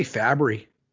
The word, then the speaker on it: Fabry: I say Fabry, like fabric softener.